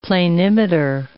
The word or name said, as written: planimeter